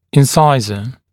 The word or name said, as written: incisor